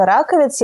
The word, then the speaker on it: Раковец: То есть э, посол Бранко Раковец, я, кстати, правильно ставлю ударение? – Да.